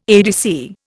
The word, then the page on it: ADC: Indian